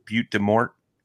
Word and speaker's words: Butte des Mortes: up there on Winnebago, and they usually stack up right there in the river channel between there and what is it, Butte des Mortes? – Yeah, there you go. Yeah. – Did I pronounce it right? – Yeah. Better than some locals.